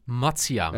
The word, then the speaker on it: Matsuyama: Hideki Matsuyama. – Ja, die U die er staat, die spreek je eigenlijk niet uit.